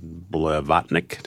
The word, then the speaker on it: Blavatnik: You are owned by a billionaire, but you, that's Len, Leonard Blavatnik. Did I pronounce him correctly? Yes. Thank you.